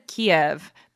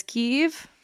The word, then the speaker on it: Kyiv: My total side question, I've always said Kiev, but it's Kyiv. – Yes, the Russian pronunciation is Kiev, but the Ukrainian is Kyiv.